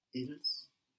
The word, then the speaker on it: ils: So we have il for he, and for they, we will just add an S to il, I-L-S. How does that sound? – Ils. Il. – Yep, it sounds the same. We don't pronounce that last S, but we write it. But it sounds the same, il.